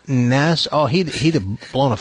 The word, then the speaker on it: NASA: It's not even pronounced. It's NASA. It's not Nassau.